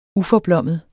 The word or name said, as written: uforblommet